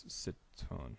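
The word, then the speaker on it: Citroën: Eiffel Tower Citrion, I believe. Kind of phonetically as I'm saying that. – Eiffel Tower Citrion. I think it's Citron. – Citrion. – Citron. – There we go.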